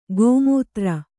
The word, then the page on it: ಗೋಮೂತ್ರ: Kannada